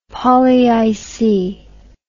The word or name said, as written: polyic